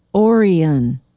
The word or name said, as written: orion